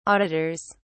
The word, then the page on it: auditors: British English